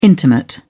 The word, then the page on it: intimate: British English